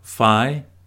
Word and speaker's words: Philemon: That book is called Philemon. Say it like Phi-Li-Mun.